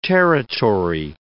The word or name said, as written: territory